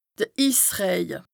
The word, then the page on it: de Issrëje: Bas-Rhin; Strasbourg